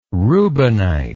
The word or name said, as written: reubenite